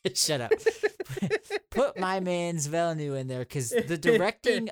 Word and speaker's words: Villeneuve: Villeneuve wasn't nominated for Best Director, which is kind of crazy because the directing in that was great.